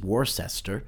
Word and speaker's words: Worcester: or as somebody would say, outside of Massachusetts, Worcester, but it is pronounced Worcester. I guess the best way you can do that is Worcestershire sauce. If you think about Worcestershire in England, that's how we use it here in the Boston area.